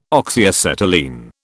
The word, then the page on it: Oxyacetylene: British English